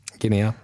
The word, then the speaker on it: Guinea: Du får rätta mig på uttalet här om jag har fel. Jag har även spelat 9 landskamper för Guinea. Var det rätt? – Guinea. – Mm. Guinea, ja. Året 2020 räddade jag mer eller mindre kvar Östersund i Allsvenskan och var en av de absolut bästa målvakterna i Allsvenskan.